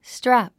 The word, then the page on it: strap: strǽp; ストラプ